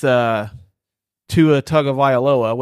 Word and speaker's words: Tua Tagovailoa: So I ended up with Tua Tagovailoa, which I probably mispronounced his name. – No. That's how I pronounce it.